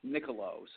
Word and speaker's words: Niccolo: But it's Michelob Niccolo, so it's a good way to know it. That's how you pronounce the name, just like the beer without the B. So it's Niccolo.